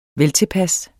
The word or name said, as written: VELTILPAS